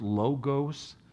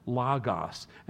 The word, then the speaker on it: logos: It is not pronounced logos. It's not long O's in the original language, it's short O's, logos, and it means word.